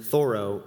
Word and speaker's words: Thoreau: Disclaimer, Thoreau is how you pronounce his last name back in the day.